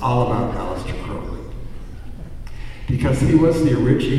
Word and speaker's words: Crowley: Anyway, it's pronounced, this is the way, because people during Crowley's lifetime were mispronouncing, so he made a little limerick thing to show how to pronounce his name properly. My name is Aleister Crowley, I am master of magic unholy. And so Crowley and unholy rhyme.